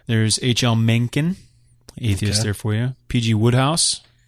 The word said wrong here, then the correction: Wodehouse: We did all the G's. Wodehouse. – Wodehouse.